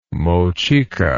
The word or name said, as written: Mochica